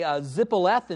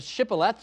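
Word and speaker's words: shibboleth: They would say zippoleth instead of shippoleth, so it seems like they had trouble with the SH sound.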